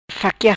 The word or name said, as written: faquia